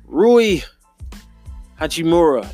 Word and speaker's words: Rui Hachimura: Rui Hachimura, that's how you say it.